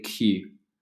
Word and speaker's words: queue: That's spelled Q-U-E-U-E. I know it's sort of, when you imagine that, how you go, how does that make Q? How does that spell Q?